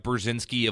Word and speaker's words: Burzynski: Again, this is Brett Brzezinski of the Brzezinski Group. That's spelled B-U-R-Z-Y-N-S-K-I.